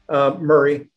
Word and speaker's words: Murray: Murray. You can Murray. Leave the A out of it.